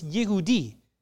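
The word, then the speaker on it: Yehudi (יהודי): Jewish in Hebrew, in Hebrew, it's pronounced, the J turns into a Y sound. It's Yehudi.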